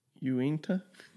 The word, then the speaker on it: Uinta: I'm actually drinking the Unita, I think is how you say it. Or how do you say that? Uinta? it's a soft U. It's a U, a soft U. INTA.